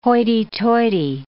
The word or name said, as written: hoity-toity